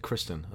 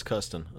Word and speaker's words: Kirsten: And like every time I've read her name, I've said Kristen. And it's Kirsten. And now I feel bad. – Kirsten, yeah.